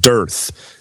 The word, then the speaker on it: dearth: It said dearth is the acceptable pronunciation.